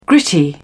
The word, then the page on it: gritty: British English